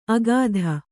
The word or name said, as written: ಅಗಾಧ